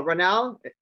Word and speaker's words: Ronell: Ronell, did I pronounce that name correctly? – It's just like tunnel with an R. – So, Ronell.